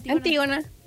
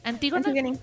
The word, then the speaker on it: Antígona: Entre los proyectos más recientes ha sido Antigone Antigone, no sé cómo se pronuncia Antígona. – Antígona.